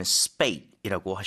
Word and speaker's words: spate: SPATE, 이거는 spate이라고 하셔야 되겠습니다.